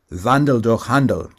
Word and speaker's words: Wandel durch Handel: The formula in German is Wandel durch Handel. It rhymes.